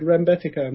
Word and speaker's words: Rembetika: You know this very old Rembetika music? – No. – Wow, that's a whole area you should dig into. – Cause how do you spell it? – R-E-M-B-E-T-I-K-A.